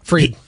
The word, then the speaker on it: Fried: I always pronounce his name properly. Like Fried.